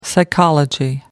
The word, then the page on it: psychology: American English